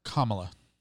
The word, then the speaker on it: Kamala: Kamala? Is that how you say it? – I believe it's pronounced Kamala.